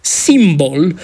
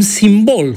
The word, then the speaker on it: simbol: Nu s-a reglementat până acolo și așa se face că unii pronunță simbol cu accentul pe prima silabă, alții însă spun simbol cu accentul pe a doua.